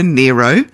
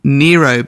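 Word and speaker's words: Nyro: I did look up Laura, how to pronounce Laura's name, and it's Laura Nero. Because she was born Laura Nigro and then changed it to Laura Nero, but spelt with a Y.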